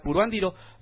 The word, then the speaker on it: Puruándiro: me están corrigiendo por acá, Omar TRC, bueno, yo también tenía entendido que era Puruándiro, pero en la agenda dice Puruándiro, así que lo pronuncié, disculpe la pronunciación, usted disculpe, manito.